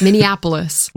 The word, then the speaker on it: Minneapolis: No, you're putting an extra N. – Minneapolis. – Yes, Minneapolis.